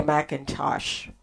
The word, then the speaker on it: McIntosh: join me on Cynthia McIntosh Ministries in YouTube.